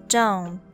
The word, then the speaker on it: dome: The next one, dome, dome. The T here is silent. We don't pronounce it, so it sounds like this.